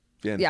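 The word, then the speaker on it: Vanhoozer: And so Gombas and I were riffing on an article by Kevin Van Hooser. Hooser? – Van Hooser? I think he said that right.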